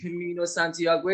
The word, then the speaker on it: Camino Santiago: و خب یه مسافرت به Walked Camino Santiago اسپانیا رفتم. I hope I pronounced that right. – You did.